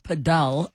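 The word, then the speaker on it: padel: And we continue in our masterclass on pedal, but it should be pronounced pedal, apparently.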